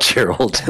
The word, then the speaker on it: Gerald: Oh, it's not Gerald. It's not. That's why all my stuff is, it's a hard G.